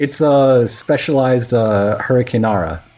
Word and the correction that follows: hurricanrana: Always forget how to say it. – Hurricanrana. – Hurricanrana. Thank you.